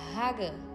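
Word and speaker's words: Hagah: A palavra meditar em hebraico diz, se pronuncia Hagan.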